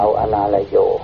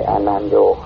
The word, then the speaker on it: อนาลโย: อนาลโยวัดตั้งกองเพนเป็นต้นนะครับต้องอ่านว่าอนาลโยนะฮะไม่ใช่อนานโยชาวบ้านบางทีก็อ่านอนานโยอนาลโย